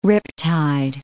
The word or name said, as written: riptide